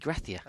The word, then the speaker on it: Gracia: Javi Gracia.